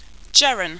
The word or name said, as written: jeran